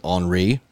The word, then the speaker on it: Henri: The governor's already basically taken himself out of the limelight other than Hurricane Henri, which is actually the correct way to pronounce it because they did spell it with an I.